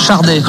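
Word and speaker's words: Sade: Par contre, ça se prononce Chardé, avec un R qui n'existe pas, mais en prenant, on dit Chardé normalement. Et je crois que d'ailleurs, elle le dit, oui, elle le dit elle-même, Chardé.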